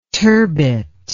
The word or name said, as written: turbit